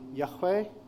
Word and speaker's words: Yahweh: Now, this word, the best way to pronounce it, we think, is probably Yahweh.